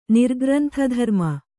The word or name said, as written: ನಿರ್ಗ್ರಂಥಧರ್ಮ